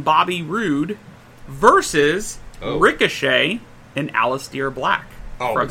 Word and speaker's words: Aleister: That's how you say his name. – It's Aleister.